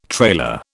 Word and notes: trailer: British English

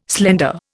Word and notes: slender: Indian

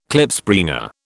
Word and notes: Klipspringer: British English

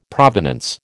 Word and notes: provenance: American English

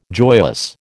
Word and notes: joyous: American English

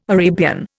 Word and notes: Arabian: Indian

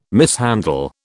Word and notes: mishandle: British English

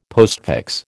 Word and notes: postfix: American English

